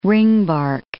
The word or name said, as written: ringbark